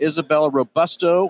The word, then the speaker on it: Isabella Robusto: Anyway, on session number two, we're with Isabella Robusto.